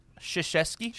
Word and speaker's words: Krzyzewski: How do you say it? – Krzyzewski. – Krzyzewski. – Krzyzewski. Krzyzewski. – Krzyzewski. – There you go.